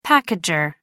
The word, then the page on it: packager: American English